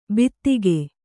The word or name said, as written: ಬಿತ್ತಿಗೆ